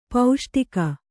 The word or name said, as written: ಪೈಷ್ಟಿಕ